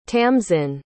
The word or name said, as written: tamsin